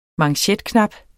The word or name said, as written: manchetknap